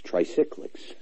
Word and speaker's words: tricyclics: So if you're interested, you want to look up triglycics, tri, tri, I'm probably not even pronouncing it right. Put you on pause let's just call them cyclics, tricyclics.